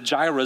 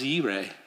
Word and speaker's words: Yireh: And we've since learned, as people study Hebrew, that Jireh is not a faithful pronunciation, but it's what's kind of stuck with us. The proper way to say it is Yireh.